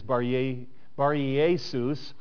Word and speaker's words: Bar-Jesus: And the pronunciation of it, actually in the Greek, is Bar-Jesus, and it just simply means son of Jesus, not Jesus our Lord.